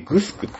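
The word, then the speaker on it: 城: 財産っていうのがあって、貧富の差がはっきりすると、じゃあ支配するもの、されるものっていう感じで、沖縄にさ、いろんなとこにグスクってあるじゃない。ね、なんか白って書いてグスクって読むんだけど、沖縄では。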